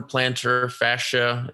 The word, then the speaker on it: plantar fascia: No, but Shams Shenaria is reporting that Heat guard Goran Dragic has suffered a torn plantar fascia.